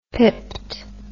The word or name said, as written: pipped